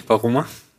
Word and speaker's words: Varoma: Dann in den Veroma. – Hm, Varoma. – Varoma. – Ja, das äh, wird leider nicht ganz so cool ausgesprochen. Veroma klingt cool.